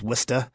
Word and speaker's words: Worcester: So, you know, Worcester becomes Wista.